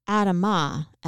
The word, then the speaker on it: Adamah: This relates to his substance of origin as the earth, or Adamah, as it's pronounced in Hebrew.